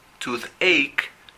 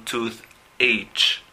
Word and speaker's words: toothache: Y solo para resaltar que la pronunciación es toothache, no toothache. Ya que hay una CH, pero muchas veces la CH hace un sonido K, como de K.